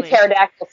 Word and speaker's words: pterodactyl: And pterodactyl silent, Lauren, just so you know.